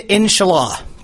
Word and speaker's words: Inshallah: Inshallah.